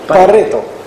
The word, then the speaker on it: Pareto: In English, maybe pronounced Pareto. I think he was Italian, wasn't he? No? – Yes. – He was Italian. So what would the Italians call him? – Pareto. – Pareto, yeah.